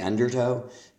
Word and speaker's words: undertow: Also, I believe the word is undertow, not undertow. When you talk about getting pulled in by the undertow, the stress is on the first syllable of undertow, not undertow.